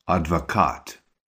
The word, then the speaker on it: адвокат: Just note how the emphasis is on the end of the word, адвокат. Say, my dad is a lawyer. – Мой папа адвокат.